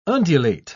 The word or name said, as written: undulate